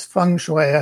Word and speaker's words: feng shui: It's not the same, though, as feng shui. I don't know if I'm pronouncing that correctly. – It's feng shui, I think, is the usual pronunciation.